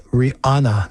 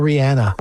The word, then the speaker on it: Rihanna: Rihanna, en Máxima 105.7, que a ella le molesta que la pronunciación de su nombre es Rihanna, no Rihanna.